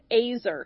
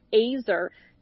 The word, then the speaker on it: ezer: And yet, when, you know, you shared this with us, Carrie, this word helper in Hebrew is the word azer. It's spelled E-Z-E-R, but it's pronounced azer.